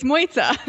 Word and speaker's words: Mojca: It's Mojca. – Mojca, that's right. Do you want to introduce yourself? – Sure. So thank you for having me. First of all, I'm Mojca.